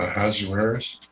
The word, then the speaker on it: Ahasuerus: Ahasuerus.